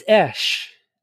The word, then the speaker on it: Eash: It's Esh. I get Ish, Esh, Ash, Cash, Dash. I get all of it.